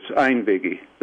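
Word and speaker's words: einvígi: Yes, that's very good. It's Einvigi.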